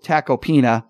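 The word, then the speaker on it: Tacopina: I want to get Jamie, your take on this, because Joe Tecopenis, sorry, I mispronounced his name again. Donald Trump's attorney, Joe Tecopena, talked about that Access Hollywood tape, you know, grab him by the P word.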